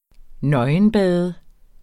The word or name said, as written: nøgenbader